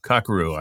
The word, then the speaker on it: Kakeru: She's a part of, and correct me if I'm pronouncing this incorrectly, Kakeru Physical Theater Company? – Kakeru. – I knew I was getting it wrong.